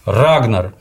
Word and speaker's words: Рагнар: Рагнар на «а», на первый слог впереди, на «ра» ударение.